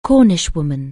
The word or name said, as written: Cornishwoman